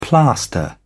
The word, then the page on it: plaster: British English